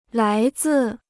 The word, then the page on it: 来自: lái zì